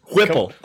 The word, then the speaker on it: Heupel: Josh Heupel, uh, just Whipple, I believe it's pronounced.